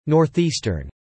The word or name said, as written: northeastern